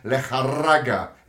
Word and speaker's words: Lejarraga: As you said earlier, the two incredible knockouts of German Lejarraga, is how you say it, actually, Adam.